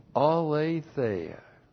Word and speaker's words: aletheia: Now for us, the only place where this glory is seen is the aletheia. A-L long E-T-H-E-I-A. Aletheia.